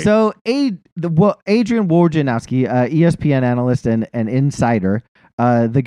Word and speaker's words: Wojnarowski: Adrian Wojnarowski. Wojnarowski. How do you say his name? – Yep. – Adrian Wojnarowski.